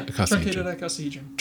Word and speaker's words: icosahedron: And inside there is that shape, that truncated icosahedron. Am I saying it right? – Yeah, icosahedron.